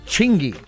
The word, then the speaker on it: Chingy: Chingy. – Chingy. – Spell it. – C-H-I-N-G-Y.